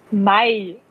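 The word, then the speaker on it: Maille: Den guten von Maillet. Oder wie spricht man es genau. aus? Mai. Mai. Okay. – Wie den Monat eigentlich.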